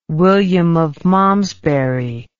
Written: William of Malmesbury

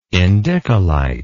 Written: indicolite

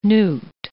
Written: newt

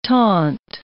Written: taunt

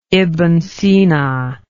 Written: ibn sina

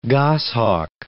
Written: goshawk

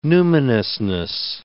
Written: numinousness

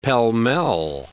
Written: pell-mell

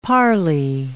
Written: parley

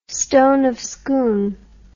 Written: Stone of Scone

